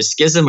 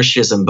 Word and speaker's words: schism: It's pronounced either schism or schism. Both are acceptable.